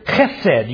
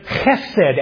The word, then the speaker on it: chesed: It's the word chesed. You've got to spit a little bit to say it right. But chesed, and it is such a rich word, filled with salvation significance.